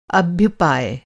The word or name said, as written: अभ्युपाय